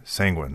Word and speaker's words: sanguine: Sanguine. That's how you say it.